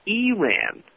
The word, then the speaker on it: Iran: In one spot created with independent New York ad agency so-and-so, intrepid forward correspondent Christine Animalpour lectures an office worker on the correct way to pronounce Iran and Iraq. With an E sound, not an I. No, it's Eran, she says.